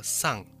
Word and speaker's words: 三: 三这个字在罗马拼音是 S A N，那发音的时候呢，念作 sun，比较接近是这个音 sun。